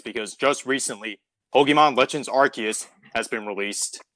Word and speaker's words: Arceus: So, fun fact, it's one of the only Pokemon to have an official canon pronunciation because arse is a term for butt. Like, it's slang for ass in Britain. So the translators flat out said, like, yeah, no, it's Arceus.